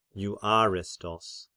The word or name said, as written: Euarestos